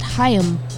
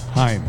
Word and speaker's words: Haim: Pronounced Haim. – Haim.